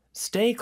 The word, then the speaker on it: stay: 일단 stay라는 단어는요. 요 T 발음이 S 다음에 오는 T는요. 보통 된소리로 하면 조금 더 발음하기가 편하세요. 그리고 그렇게 발음하는 원어민들도 실제로 많습니다. 그래서 stay. 근데 여기서 중요한 거는 S 발음은 아주 약하게 해줘야 돼요. S 이렇게 그 다음에 day가 더 크게 들리는 거죠.